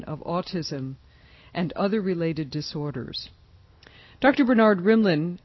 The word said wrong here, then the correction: Rimland: which is spelled R-I-M-L-A-N, like it sounds, Rimland.